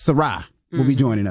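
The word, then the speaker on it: Sy'Rai: Now, when we come back, Soraya. Did I say that right? – Sarai. – Let me make sure. – Like, hey, Sarai, Sarai. It's just Sarai.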